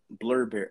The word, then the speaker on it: Blubrry: So you have places like Podbean and FeedPress, Libsyn, Blurberry. Oh, was it like blueberry? It's spelled B U uh, B L U B R R Y.